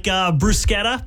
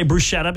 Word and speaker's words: bruschetta: There are other ones too, though, like bruschetta. People say bruschetta because of the way it's spelled. – Bruschetta. But you bruschetta. – Have to say it with an Italian accent.